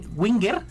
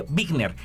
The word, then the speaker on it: Wigner: Ya, y bueno, de esta palabrita que también me llamó mucho la atención, que dice, para el análisis de la distribución de Wigner, sí lo pronuncié, bien, ¿verdad? – En realidad es Wigner. – Wigner, qué sí.